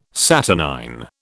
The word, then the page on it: saturnine: British English